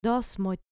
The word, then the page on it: dos moi: grec